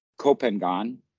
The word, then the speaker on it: Koh Phangan: The Thai people pronounce it that way because that's how it's written. But the Westerners always pronounce it Koh Phangan. But Koh Phangan is wrong. It's Koh Phangan.